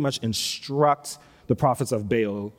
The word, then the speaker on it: Baal: And they begin to worship this god Baal, or Baal, as could also be pronounced.